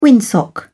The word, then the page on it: windsock: British English